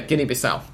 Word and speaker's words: Guinea-Bissau: Guinea-Bissau. – Thank you. – Or I don't know how it's pronounced in English, but... – Yeah, Guinea-Bissau. – Guinea-Bissau. – It's the same.